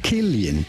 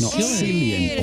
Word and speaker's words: Cillian: Yes, it is to be pronounced Killian, not Cillian or anything else. Rather, Killian.